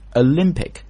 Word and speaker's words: Olympic: Olympic，注意 Olympic 那个前面那个不是 Olympic，很多人说奥运会 Olympic， No， it's Olympic，发 a 这个音，因为这个重音是在 lim 上面。 Olympic、